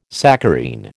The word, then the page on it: saccharine: American English